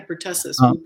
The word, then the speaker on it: pertussis: The DPT one, and particularly, they talk about pertussis, if I'm saying that right. – Yeah. – Is that right? – Yeah, pertussis.